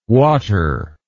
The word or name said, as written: watter